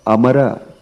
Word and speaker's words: Amara: But Pundanam is pronouncing it as Padmanabha Mara Prabhu. He didn't look into the Amara. He pronounced it Padmanabha Mara Prabhu.